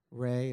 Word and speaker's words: Re: His name was Ra. It was more properly pronounced Re. I had an Egyptologist tell me. Re is the more proper pronunciation.